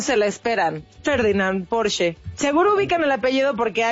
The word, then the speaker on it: Porsche: Y solo como un dato cultural, déjenme les cuento que es alemana y su correcta pronunciación es, escuchen, Porsche. ¡Ándele!